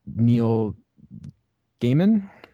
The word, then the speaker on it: Neil Gaiman: And he worked with Neil Gaiman.